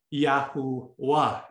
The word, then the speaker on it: Yahuwah: So the W, its pronunciation is the oo sound, not the W sound. Hence, you get Yahuwah.